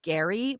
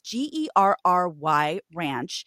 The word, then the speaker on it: Gerry Ranch: It's spelled Jerry Ranch, though I've heard it pronounced Gary, but it's Gerry Ranch in Camarillo.